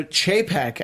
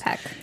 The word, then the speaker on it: Chapek: So, you know, this guy, you know, Chapek, as we're told it's pronounced. – Chapek.